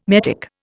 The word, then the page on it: metic: Indian